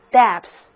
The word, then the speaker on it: depth: 注意它的读音不是念 deep，而是念 depth。